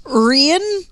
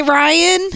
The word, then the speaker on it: Rian: Rian. – Rian with the accent over the I. – Some people will say Ryan.